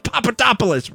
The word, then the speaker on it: Papadopoulos: I pronounced Papadopoulos right.